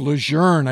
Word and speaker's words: Lejeune: He was Major General John A. Lejeune, or pronounced Lejeune, I guess, if you're a Marine Corps purist.